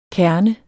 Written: kerne

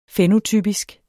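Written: fænotypisk

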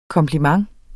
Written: KOMPLIMENTENS

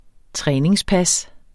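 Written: træningspas

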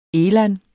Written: eland

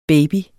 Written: baby

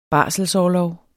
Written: barselsorlov

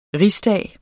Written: RIGSDAGS

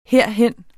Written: herhen

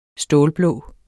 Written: stålblå

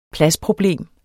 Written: pladsproblem

